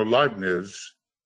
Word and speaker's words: Leibniz: Although, you know, Leibniz, it's actually not pronounced Nitz, it's Niz.